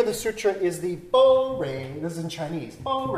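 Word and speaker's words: Bo Rei (般若): But then it's weird because modern Mandarin, they're pronounced Bo Rei, which doesn't sound anything like pranya.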